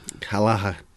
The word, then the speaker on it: Kelleher: Kelleher.